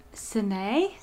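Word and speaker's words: Sanaë: How would you say that? I'm going to show you the cover. – Sen- Sine. Sine, yeah.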